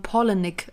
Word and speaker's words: Palahniuk: Im Englischen sagt man Palahniuk, als wären das zwei Namen, Paula und Nick.